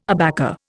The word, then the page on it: abaca: Indian